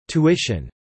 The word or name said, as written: tuition